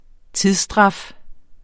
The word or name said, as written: tidsstraf